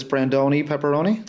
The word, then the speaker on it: Brandoni Pepperoni: Right now it's somewhat virtual, but it's Brandoni Pepperoni.